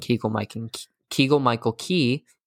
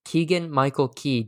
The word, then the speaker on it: Keegan-Michael Key: It's a millennial-friendly send-up of Lerner and Lowe's Brigadoon, starring Cecily Strong and Keegan-Michael Key. Wow. Wait, I said that wrong again. Keegan-Michael Key, damn it.